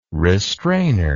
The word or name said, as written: restrainer